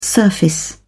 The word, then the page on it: surface: British English